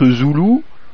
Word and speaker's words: Zulu: Zulu, dans le texte de l'UIT, ça s'écrit Z-U-L-U, Zulu. Mais se prononce, d'ailleurs c'est bien écrit dans le texte de l'UIT, se prononce Zoulou, et entre parenthèses il y a marqué Zoulou, prononcé Zoulou.